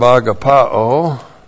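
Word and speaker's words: agapaō: The verb agapao A-G-A-P-A,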